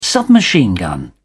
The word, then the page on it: submachine-gun: British English